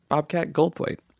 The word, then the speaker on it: Goldthwait: Bobcat Goldthwait.